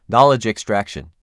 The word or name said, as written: Knowledge extraction